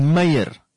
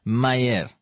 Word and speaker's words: Meir: If you read that name, in Afrikaans, it's Meir. But it has to be pronounced in a specific manner, Meir.